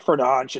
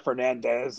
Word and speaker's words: Fernandes: It's like Fernandes. It's not a Fernandez. It's like a Fernandes with like a D-C-S-H something or other, according to the Euro podcast I listened to this summer.